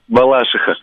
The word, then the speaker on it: Балашиха: Слушайте, Балашихи или Балашихи? Я все время путаюсь, как с Реутовым и Реутовым. – Балашиха. – Балашиха, вы считаете? По-моему, Балашиха.